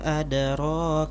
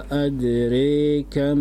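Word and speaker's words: أدراك: Nah, di sini juga ada perbedaan pengucapan ya, yaitu riwayat Syu'bah dia memakai imalah ya, seperti yang sebelum-sebelumnya. Wa ma adrika mal hutama. Wa ma adrika mal hutama. Iqra bil imalah.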